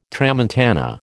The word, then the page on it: tramontana: American English